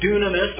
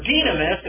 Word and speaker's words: dynamis: Ysilon, sometimes pronounced Ysilon, is the U in English, but in the Greek, yes, can be pronounced both ways, and so you get dunamis or dynamis.